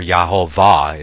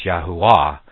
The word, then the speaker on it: Yahuwah: Yahuwah, he's got it pronounced here, Yahuwah, or Yahovah, if you read it in contemporary, if you put the contemporary pronunciation of letters to the pronunciation that they have here, it's Yahovah, but we know that the V is not pronounced in the Hebrew, so it's Yahuwah.